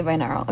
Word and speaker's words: binaural: Is it binaural? Audios at some point? Binaural? Okay, binaural. Okay.